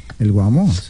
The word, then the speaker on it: Guamo: Entonces, ¿qué Bésame, el Guamo? Se dice así. – Chao, Ana, chao. – Sí, claro, el Guamo.